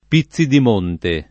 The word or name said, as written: Pizzidimonte